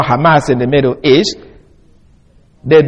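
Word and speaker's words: Hamas (חמס): Some will write it as C-H-A-M-A-S, but because it's what it's a, more or less a guttural sound in the Hebrew, just like ka sound. Here, some people will spell it this way, just like H-A-M-S. And now when we spell it like that, you see what it sounds?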